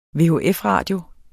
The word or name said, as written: vhf